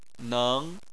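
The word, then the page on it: neng: tone 2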